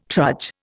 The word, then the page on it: trudge: Indian